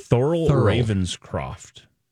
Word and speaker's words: Thurl Ravenscroft: How do you say his name? – Thurl Ravenscroft or Croft? – Thurl Ravenscroft.